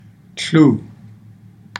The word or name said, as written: tluu